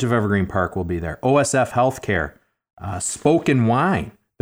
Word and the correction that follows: Spoken Vine: They're opening that new wine bar. – Spoken Vine. – Spoken Vine. – I said their name wrong a couple of weeks ago on a different show, and they got very mad at me.